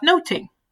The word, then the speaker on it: nothing: Eh, därav, eh, de hade ett litet förord där, där de sa att ehm, i original, alltså på engelska med den tidens språkdräkt så heter den inte Much ado about nothing utan den heter, man uttalar den Much ado about noting. Mm.